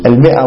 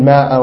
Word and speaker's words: المئة: وبعد عطائك المئة الرتاعة، لا تلفظ هذه الألف، المئة والمئتين لا تقول ماء ومائتين، هذه لا تلفظ، تكتب لا تلفظ، لأن البعض ما بيكتبها خطأ والبعض بيكتبها ويلفظها خطأ. الصحيح أنها لا أنها تكتب ولا تلفظ.